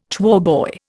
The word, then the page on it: choirboy: Indian